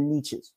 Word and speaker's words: niches: Or if you're in the UK listening to this broadcast, if you want to go to the beaches, you've got to work the niches. Right. Because that's how they pronounce it in the UK.